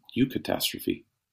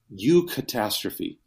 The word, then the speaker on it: eucatastrophe: But Tolkien coined a term, eucatastrophe,